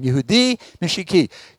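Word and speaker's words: Yehudi Mishihi (יהודי משיחי): Yehudi Mishihi. I got the accent wrong. Yehudi Mishihi.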